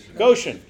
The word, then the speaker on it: Goshen: It's, at that time, it would have been Gershon. No. – Goshen. – I can't make that long O fit my word today. Goshen.